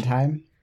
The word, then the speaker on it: Sondheim: Why did I look up the pronunciation? – Sondheim.